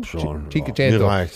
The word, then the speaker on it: Cinquecento: Vorher hatten wir so ein Cinquecento. Ist er richtig ausgesprochen? – Cinquecento.